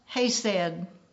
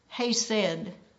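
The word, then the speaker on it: Chesed: But it's spelled C-H-E-C-E-D. I had to get online and ask the question, how is this pronounced? It's pronounced Hesed, Hesed.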